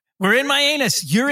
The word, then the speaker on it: Mianus: M-I-A-N-U-S. – Oh, that can't be the way you pronounce it, though. – Dude. – Mianus.